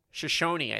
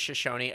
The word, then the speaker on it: Shoshone: Have you ever heard of the Shoeshine tribe? – Shoshone, I think. – Is that how you say it? – Yeah, I think it's Shoshone. It's a S-H-O-S-H-O-N-E. – Yeah, Shoshone.